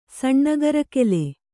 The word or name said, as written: ಸಣ್ಣಗರಕೆಲೆ